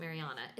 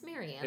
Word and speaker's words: Marianna: Somebody pronounce it Mariana. It's Mariana.